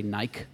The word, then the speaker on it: Nike: So a footwear examiner might be just trying to say, it's this Nike model, or as the Brits say, Nike.